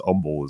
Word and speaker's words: Humble: This is really funny if you live in Texas, because Humble is a place. I don't know. It's weird. Spelled humble, pronounced humble.